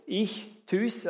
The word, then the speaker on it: Ichthys: Der Fisch bedeutet, oder auf Griechisch heißt Fisch Ichthys, und diese vier Buchstaben, die ihr dort seht, Ichthys, also das Y wie das Ü ausgesprochen, das bedeutet Jesus Christus, Gottes Sohn und Erlöser, das sind immer die Anfangsbuchstaben.